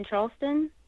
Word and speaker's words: Charleston: Facebook, Adrienne Charleston.